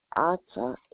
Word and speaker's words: Arta: Arta, A-R-T-A, Salma, S-A-L-M-A.